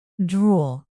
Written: drool

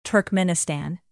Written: turkmenistan

